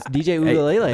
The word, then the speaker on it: Uiagalelei: Look at that pronunciation, guys. DJ Uiagalelei. – That was pretty good.